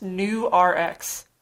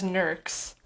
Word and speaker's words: Nurx: So that's actually supposed to be pronounced New RX, like new prescription. – Mm. – But it definitely reads as Nurx.